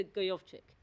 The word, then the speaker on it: Gojowczyk: Peter Gojowczyk. Exactly. – Ah! That's the name. That's the pronunciation.